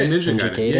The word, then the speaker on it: Ninja Gaiden: And then lastly, Ninja, I don't know how to pronounce it, Ninja Gaiden? – Oh, yeah, Ninja Gaiden.